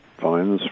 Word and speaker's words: Fiennes: So my original French cousins, although it's spelt the same way, over here it's pronounced Fiennes,